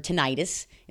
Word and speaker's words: tinnitus: Okay, so what they're claiming it causes is tinnitus. Many of you guys have heard tinnitus. It's actually pronounced tinnitus.